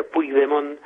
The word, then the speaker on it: Puigdemont: Carles Puigdemont, así se pronuncia Puigdemont en catalán, presidente de la Generalitat, que es precisamente el gobierno autónomo de Cataluña.